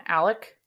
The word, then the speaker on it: Alex: There is mom, Maggie, dad, Alex, which people in the show call him Alec. – Yeah, I think it is Alec. – It's spelt like Alex, so I just say Alex a lot. There is an X, yeah.